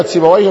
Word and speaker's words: سيبويه: إذن كلمة سيبويه تنطق من إما تنطقها انطقها مبنية على الكسر، سواء وقعت فاعل وقعت مفعول وقعت مجرور هي تلزم البناء، يعني دائما الكسرة دي ملزماها تلزم البناء.